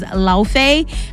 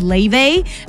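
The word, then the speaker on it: Laufey: First of all, Norse mythology, they're saying it is Lofey. Icelandic people are saying it is Leve.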